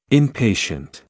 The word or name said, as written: inpatient